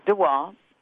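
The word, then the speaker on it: de Waal: That Dr. DeWall, am I pronouncing his name right? – DeWall. – Yeah, DeWall.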